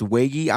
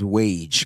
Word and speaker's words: Waege: That's how you say his last name. It's Wagy. I was pronouncing him as Wage because he just sounds so much better. But Wagy's cool. No, Wagy. Yeah, it's Wagy. – W-A-E-G-E.